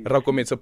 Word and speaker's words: Rakometsi: Rakometsi, apologies for that.